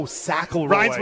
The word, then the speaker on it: Sackleroy: S-A-S? Oh, Sackleroy. – Rhymes with Mac.